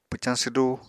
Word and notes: pwcañ swdu: apatani

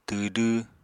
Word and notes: twdw: apatani

